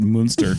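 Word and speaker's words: Münster: And anytime the U has two dots over it, I have to pronounce it Munster. – I think anyway, that is how you're supposed to pronounce it. So. – I know, with like a little more emphasis on the moo.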